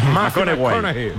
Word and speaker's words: McConaughey: Mateo McConaughey. – Sí, sí, sí, sí. – Hay que ir corrigiendo la pronunciación. – McConaughey. McConaughey. Bueno, pues el Mateo.